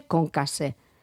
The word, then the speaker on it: concassé: que sepamos que se llama corte concassé. Y la S bien pronunciada porque tiene dos S, es en francés.